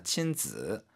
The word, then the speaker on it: 亲子: 亲子 발음 지 이게 삼성어로 발음해야 됩니다. 亲子 해외여행.